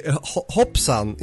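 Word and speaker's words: hoppsan: Is this the last post you posted that you commented Hopsan? Is that how it's pronounced? – Yeah, exactly. Hopsan in Swedish is oops.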